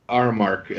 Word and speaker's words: Aramark: So the guy that came up with this and his right-hand man sold the idea to Automatic Retailers Association, or Aramark. They're the white trucks you see driving around town. – Oh, yeah. – Yep. So that was the parent company that then... – Aramark. – Aramark, I think, is the true pronunciation.